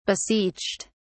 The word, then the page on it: beseeched: British English